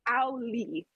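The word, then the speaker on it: Auli'i: It's Ali. – Ali? – Oh. Ali. – Okay. – She said, like, ow, as if you hit, stub your toe. – Okay. – And then Lee.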